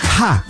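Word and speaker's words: ha (ห้า): Sí, el número 5 se pronuncia allá en Tailandia como ha, H-A, en tailandés.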